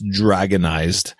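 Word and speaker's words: Dr460nized: So I downloaded the, Garuda KDE, DR460 NIZED, which would be pronounced dragonized,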